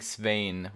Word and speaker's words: Svein: I mean, Sven is like the present day name. I think Svein with like this, that vowel sound is like how they said it back then.